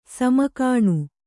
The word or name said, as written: ಸಾಮಗಾನ